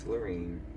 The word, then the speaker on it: Laureen: Okay, upon looking up a YouTube video, it is Loreen.